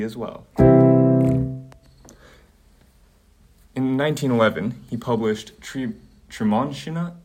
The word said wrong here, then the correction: Treemonisha: uh, sorry, this is a weird name to pronounce, Treemonisha, sorry, the opera.